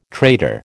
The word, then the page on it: traitor: American English